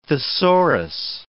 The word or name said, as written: thesaurus